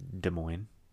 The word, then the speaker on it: Des Moines: Des Moines. – All right, I thought you pronounced the S's for some reason. – No, I would never say the S's.